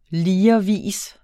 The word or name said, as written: ligervis